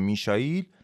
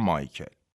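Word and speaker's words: Michael: تلفظ آلمانی اسم کوچیک شوماخر میشائیله ولی جاهای دیگه دنیا با تلفظ انگلیسی میگن مایکل توی این قسمتم من به جای تلفظ آلمانی میشایل از تلفظ رایجی که توی زبان فارسی جا افتاده استفاده میکنم و میگم مایکل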